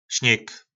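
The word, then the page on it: Snyg: nářečí Po našimu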